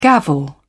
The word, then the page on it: gavel: British English